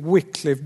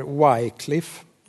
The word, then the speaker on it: Wycliffe: Och en del av er känner säkert till Wycliffe bibelöversättare. En del säger Wycliffe, jag tror det ska uttalas Wycliffe.